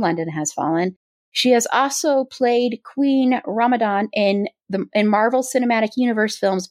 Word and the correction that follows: Ramonda: Sorry, go ahead. I know I pronounced that. – Okay, Ramonda. – Thank you. Yes, I was like, I know I'm mispronouncing that.